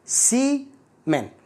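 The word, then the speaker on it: salmon: السلمون بنقرأ احنا بالعربي سلمون لكن هو بالإنجليزي سيمون سي من سي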